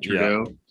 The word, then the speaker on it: Trudeau: Is Trudeau, that's how you say his name, right? Trudeau. – Yeah. Yeah.